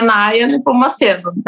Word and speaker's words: Naya Nepomuceno: Uh, o meu Instagram, eu vou pedir para vocês colocarem depois em algum lugar se tiver como, porque meu nome é bem complicadinho, para as pessoas me seguirem, mas é Naya Nepomuceno, né? Bem, bem fácil, assim como se fala.